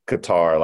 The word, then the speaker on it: Qatar: I'm from Denmark, South Carolina, and somebody told me it was Qatar, not Qatar, like I've been calling it my whole life.